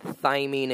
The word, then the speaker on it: thymine: Is it adenine, cytosine, thymine, and guanine? – Right.